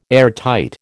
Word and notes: airtight: American English

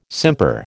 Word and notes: simper: American English

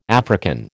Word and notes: African: American English

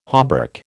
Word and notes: hauberk: American English

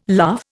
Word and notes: laugh: Indian

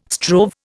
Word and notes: strove: Indian

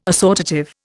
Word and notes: assortative: Indian

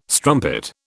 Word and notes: strumpet: British English

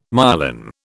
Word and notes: myelin: British English

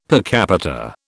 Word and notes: per capita: British English

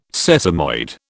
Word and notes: sesamoid: British English

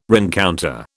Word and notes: rencounter: British English

